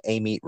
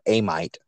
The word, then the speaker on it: Amite: On the east side, you have the Amite River, or Amite, as people that don't know how to pronounce it.